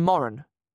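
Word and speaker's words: Moran: His real name's Dean Moron. Rhymes with Warren.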